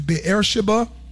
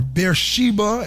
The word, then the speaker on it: Beersheba: And when he saw that, he arose and went for his life and came to Beersheba, or Beersheba, as we variously pronounce it, which belongeth to Judah, and left his servant there.